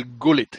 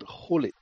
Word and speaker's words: Gullit: Because I know that in, in, Holland you pronounce the G's like H's. So it's not Louis van Gaal, it's Louis van Hall, And it's not Ruud Gullit, it's Rud Hulit.